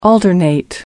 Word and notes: alternate: American English

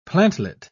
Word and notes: plantlet: British English

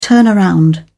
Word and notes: turnaround: British English

